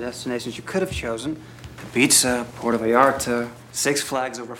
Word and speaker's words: Ibiza: It's pronounced Ibiza.